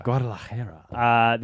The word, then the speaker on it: Guadalajara: Guadalajara.